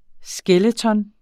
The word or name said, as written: skeleton